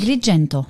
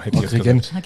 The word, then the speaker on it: Agrigento: Agrigento. – Genau. – Du kannst es besser. Auf Deutsch wird man es Agrigent aussprechen, oder? – Agrigento.